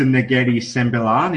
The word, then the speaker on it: Negeri Sembilan: So you moved to Negeri Sembilan in Malaysia in 2016. – Terrible pronunciation, but we'll run with it. – Yeah. please edit. No. How do you say it? You did well.